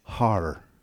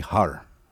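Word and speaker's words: har (הר): In the Hebrew, the word for mountain and hill is har. Everyone say har.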